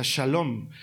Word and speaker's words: shalom: Es bien interesante porque los hebreos, los judíos, se saludaban aún hoy, lo siguen haciendo con un vocablo que se pronuncia shalom y que lo hemos traducido por paz, pero que literalmente significa algo mucho más completo y complejo.